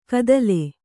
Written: ಕದಲೆ

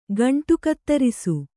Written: ಗಂಟುಕತ್ತರಿಸು

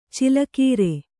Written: ಛಲಗಾರ

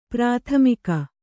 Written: ಪ್ರತಿಮುಖ

